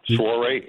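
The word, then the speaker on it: soirée: Yeah, I think your surprise picture, whatever, is a Swaree Cowie. – Say it again. – Oh, I'm not personal. I don't know about the pronunciation. Swaree, S-O-I-R-E-E. – You got it.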